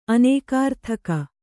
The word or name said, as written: ಅನೇಕಾರ್ಥಕ